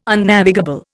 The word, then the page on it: Unnavigable: Indian